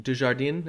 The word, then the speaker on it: Dujardin: I saw, you know, um, Jean uh, Dujardin. Is that how you say it? Uh huh. – Let's right. say that's right.